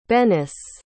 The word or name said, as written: Bennis